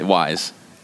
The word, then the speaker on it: Wise: Some people still call it Weiss. – Weiss. – Right. – Yeah, Wise.